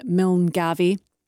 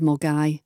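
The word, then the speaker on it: Milngavie: One that I hear often mispronounced by people down south is Milne Gavy instead of Mulgai.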